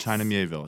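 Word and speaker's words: China Miéville: China Mieville. Is that how you pronounce it? – It sure is.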